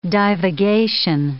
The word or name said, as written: divagation